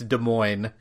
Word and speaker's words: Des Moines: Out of Des Moines. Iowa. That's even stranger. Jesus. All right, Mother. – Way to disrespect that city. It's Des Moines. There is no S sound to that city.